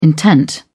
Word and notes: intent: British English

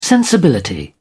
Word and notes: sensibility: British English

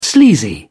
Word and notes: sleazy: British English

